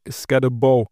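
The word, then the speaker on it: Skattebo: Aber kurzer Einwurf, ich habe ChatGPT gefragt und die sagen, dass der Arizona State Running Back Scatterbow ausgesprochen wird. Das B-O wird wie B-O-W ausgesprochen.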